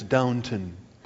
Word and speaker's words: Downton: I pronounce it Downtown Abbey, which makes the punchline, right? It's my wife's working on me. It's Downton, something like that.